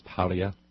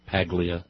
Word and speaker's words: Paglia: I don't think you pronounce the G. I think it's one of those silent Italian G's, Paglia. Pagliacci, you know, you don't pronounce the G. Paglia.